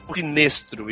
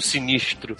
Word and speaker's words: Sinestro: Tudo bem, é uma coisa, mas Sinestro e não Sinistro. – É porque o nome dele é Sinestro. – Não é o nome, Sinistro. É Sinestro.